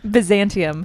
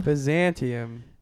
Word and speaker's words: Byzantium: Byzantium. – Byzantium.